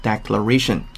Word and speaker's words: declaration: 读音上一定要注意一下，跟 declare 不是一脉相承下来的，重音发生了变化，读成 declaration。